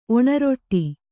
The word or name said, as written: ಒಣರೊಟ್ಟಿ